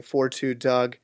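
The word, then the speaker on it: 42 Dugg: Yeah, he didn't announce a date yet, but it was called $1 Million Worth of Game, featuring 4-2 Doug. I've been pronouncing it 42, but I saw in the Big Sean interview with Drink Champs, it's 42 Doug.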